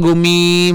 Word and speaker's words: Goomi: Atau G-O-O-M-I, Gumi. Gumi, dibacanya Gumi.